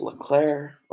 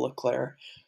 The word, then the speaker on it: Leclerc: Anyway, moving on to P9 is going to be Charles Leclerc, or actually, I pronounced that wrong. It's Charles Leclerc. It's a real pronunciation said by him.